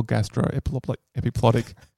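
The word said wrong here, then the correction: gastroepiploic: It was worth it just for the mispronunciation of gastro-epiploic.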